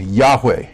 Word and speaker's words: Yahweh: But the Hebrew form of I am is more appropriately pronounced Yahweh or Yahweh. Yahweh is maybe a better way to say it.